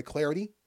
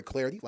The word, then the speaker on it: Clardy: Best way to do that is to hit me up on Twitter, at Troy Clardy, at Troy Clardy, last name is spelled C-L-A-R-D-Y.